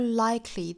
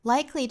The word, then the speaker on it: likely: 后面最后一句 they were more likely to stick, likely 这个词呢我们要注意不要读成了 likely, 在 k 的后面不要加元音，应该是 k 跟后面的 li 紧挨着发音的 cli, 应该是 likely。